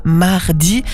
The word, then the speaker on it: Maredi: Et nous étions avec la nouveauté zurichoise du groupe zurichois Mardi. Je le prononce comme ça parce que ça s'écrit M-A-R.E-D-I.